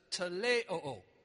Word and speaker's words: teleioo: It's teleo. Okay? Teleo. It's spelled T-E-L-E-I-O-O. Teleo.